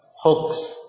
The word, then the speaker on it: hoax: Bacanya yang benar gitu. Apa? Hoax. Kita aja terbiasa dengan apa?